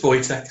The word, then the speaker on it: Wojtek: Just Wojtek is fine.